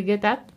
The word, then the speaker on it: Get Up: Y es el Get Up, ¿lo he dicho bien? – Sí.